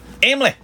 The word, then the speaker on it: Emily: No, three syllables. Emily. – Yeah, but... – And then Emmy is two. – But we're here in Arkansas. – Emily.